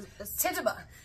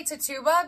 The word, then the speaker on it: Tituba: I always say Tituba because that's how I read it, but I'm pretty sure the proper pronunciation is Tituba. And for anybody who's out there offended that I keep saying Tituba, I apologize. It's kind of happened. The last four letters of her name are T-U-B-A.